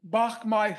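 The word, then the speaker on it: Bach Mai: During Christmas 1972, the United States bombed Bachmai Hospital. For your listeners who are interested, it's spelled B-A-C-H, new word, M-A-I.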